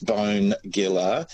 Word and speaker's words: Bonegilla: But look, Let's start from the historical version, and it's actually an Aboriginal name, and it is pronounced Bonegilla.